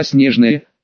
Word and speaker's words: Снежное: А вот название города Снежное, наоборот, с ударением на последний слог.